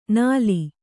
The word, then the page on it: ನಲಿ: nali